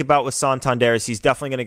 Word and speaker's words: Santander: Pronunciation, Santander. So we were right about that.